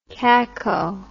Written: caco

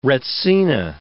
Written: retsina

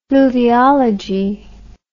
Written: fluviology

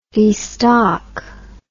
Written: destock

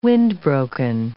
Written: wind-broken

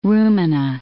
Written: rumina

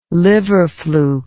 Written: liver fluke

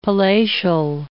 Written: palatial

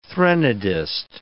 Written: threnodist

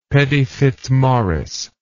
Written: pettyfitzmaurice

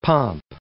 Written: pomp

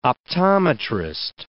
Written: optometrist

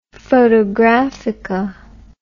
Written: photographica

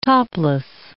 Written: topless

